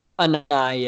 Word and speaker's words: Anae: And a huge part of that was because of Bradley Anae. Did I pronounce that right? – Anae, yeah.